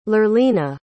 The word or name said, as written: lurlina